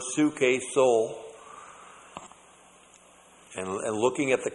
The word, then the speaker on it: psuche: P-S-U-C-H. Long E, suke.